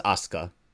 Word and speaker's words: Asuka: It's pronounced Asuka.